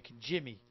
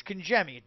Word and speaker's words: Congemi: out west, they call me Congemi. – Congemi. – And I kind of dig that, you know. It's Congemi, gem, like gem, G-E-M, gem, Congemi.